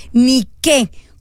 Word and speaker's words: Nike: Es una mujer que representa la Victoria, que se llama Nike, con acento en la E. Nike como Nike, como de los zapatos Nike.